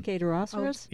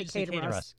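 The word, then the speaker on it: Kayaderosseras: The Cater-Rosseras? – Oh, yeah. – The Cater-Rosseras, but people there don't usually say that. They say Cater-Ross.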